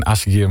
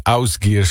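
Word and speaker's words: Ásgeir: Die zei, het is geen Asgir, maar het is Ausgir, zo spreek je dat in het IJslands uit.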